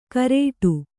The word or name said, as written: ಕರೇಟು